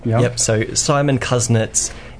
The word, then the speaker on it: Kuznets: Kuznets.